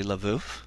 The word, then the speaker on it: Lviv: I know that you were um, were born in what is now Ukraine, in a city I believe, if I'm pronouncing it correctly, Lviv. – That, uh, Lvov, Lvov, that's right. Um, that's correct, yeah.